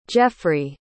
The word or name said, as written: Jeffry